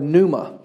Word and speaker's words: pneuma: That word in the Greek is actually the word pneuma. It's P-N-E-U-M-A. Pneuma, I know it doesn't sound anything like it's spelled, but P-N-E-U-M-A.